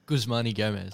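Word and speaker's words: Guzman y Gomez: Guzmani Gomez.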